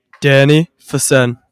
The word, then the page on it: Danny Fisenne: male